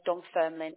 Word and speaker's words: Dunfermline: So first memories of care was being sent to an assessment centre, and yeah, that was called Dunfermline, if I can pronounce that right.